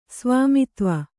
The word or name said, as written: ಸ್ವಾಮಿತ್ವ